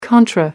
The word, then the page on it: contra: American English